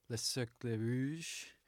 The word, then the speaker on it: Le Cercle Rouge: The movie we watched for today is The Red Circle, or Le Cercle Rouge in French. I don't know if that's how you pronounce it, but close enough.